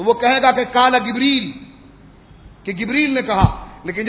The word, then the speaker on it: جبریل: وہ وہاں گاف نہیں پڑے گا, جیم پڑے گا. اس لیے کہ جبریل جس طرح لکھا جاتا ہے تو جیم کا یہ تلفظ جبریل کرنا, اس کو جبریل نہ کہنا.